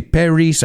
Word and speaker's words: Paris: And I've heard my students say Paris. I've heard them say a lot of different variations of that. The pronunciation in English, again, is Paris. Paris, France.